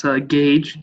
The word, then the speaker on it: Gage: So if people have a pen nearby, that's Gage, G-A-G-E. Miskimmin, M-I-S-K-I-M-E-N.